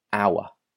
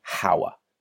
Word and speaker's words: hour: The first one is hour, as in 60 minutes. We say an hour. because the H is silent. We don't say hour, we say hour.